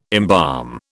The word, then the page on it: embalm: British English